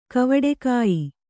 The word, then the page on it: ಕವಟೆಕಾಯಿ: kavaṭekāyi